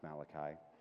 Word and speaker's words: Malachi: I know it's pronounced Malachi. Just checking to see if you're awake.